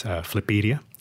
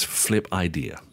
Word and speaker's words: Flipedia: It's pronounced as Flipedia. – Flipedia. – Yes. – So obviously I read it and it was Flip Idea. – Yes. Right? It's a bit of a wordplay there too.